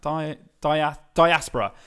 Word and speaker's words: diaspora: Yeah, it's that diasporia, which is one of the... Is that the right way of pronouncing that? Diaspora.